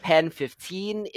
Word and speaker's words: PEN15: For this week, we'll be talking about a Hulu cringe comedy, heavy emphasis on cringe, called Pen 15. It is spelled like penis, you know, P-E-N 1-5, Pen 15.